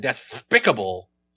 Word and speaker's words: despicable: Because before Daffy Duck, the word was generally pronounced despicable. – Really? – But because of the way Daffy said despicable, they actually move that emphasis to the second syllable, interesting.